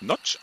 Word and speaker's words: Nagy: warum Nagy eigentlich Notch ausgesprochen wird, hat er mir in unserem heutigen Lagerfeuergespräch sehr enthusiastisch erläutert.